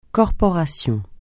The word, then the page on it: corporation: francais